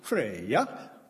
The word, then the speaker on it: Freya: Our Norwegian friends told her that her name was supposed to be pronounced Freya, but I couldn't go out in the backyard to call her in Freya.